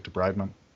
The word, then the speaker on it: debridement: It could be debridement as well, because sometimes, like, is that how you say it, debridement? – Yep.